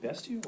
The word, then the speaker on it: bestial: Is it bestial or so bestial? – You can say it either way. – It's spelled like beast, though. Right, I mean, right, but it is from beast, yes.